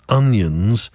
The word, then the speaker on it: Onions: One of my friend's surnames is Onions, but he says it's pronounced O'Nions, as in an Irish name, O apostrophe Nions.